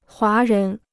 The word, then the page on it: 华人: huá rén